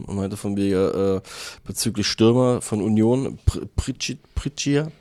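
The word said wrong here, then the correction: Prtajin: fuck, ich habe den Namen safe falsch ausgesprochen, Prantic.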